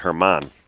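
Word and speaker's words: Germán: Yep, by German Sepoldova, freshman from the Bronx, New York. – I think he pronounces it Herman. – Is it Herman? – Yes.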